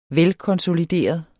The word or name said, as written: velkonsolideret